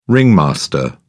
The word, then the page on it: ringmaster: British English